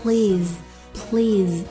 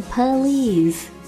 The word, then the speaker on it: please: 接下来想要讲到的一个单词 please，好多人都读不好的，会读成 police，警察。